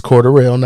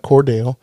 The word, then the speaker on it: Cordarrelle: And it's Cordarrelle, not Cordell. – It's Cordell. – Anywho. – You can't pronounce every syllable. – You're supposed to. That's your thing. – Not with his name.